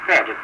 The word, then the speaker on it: Pferd: P-F-E-R-D, ferret. The P is silent.